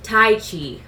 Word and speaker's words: Tyche: So Tai Chi which is spelled T Y C H E, is also written Tai Tai Chi as in T Y K H E, but it's pronounced Tai Chi, like the martial arts.